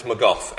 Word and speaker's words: McGough: So my name's Lee, as it says there, L-E-I-G-H. Thanks, Mum and Dad, for giving me a girly name. And then it's McGough, M-C-G-O-U-G-H.